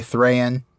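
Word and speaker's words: Thráin: And we said that we are, we say Thrayan, we say Dayan, we say Owen and Glowen.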